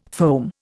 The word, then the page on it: foam: Indian